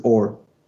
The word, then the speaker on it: Or: My name, like in Hebrew, you say all, and when I say all, eh, nobody understands this. It's like oh, all. so every time I need to say my name to somebody new, I'm like, my name is Or, O R, every freaking time, and it's like wow, like the easiest name.